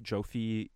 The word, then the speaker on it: Joffe: Joff, Joffe, J-O-F-F-E. – Oh, J-O-F-F-E.